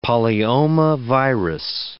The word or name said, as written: polyomavirus